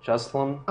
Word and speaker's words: Juslam: J-U-S-L-A-M, Jeslum. – Oh, Jeslum. – And then his last name is G-R-I-N-T-I-S.